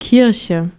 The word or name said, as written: ilesia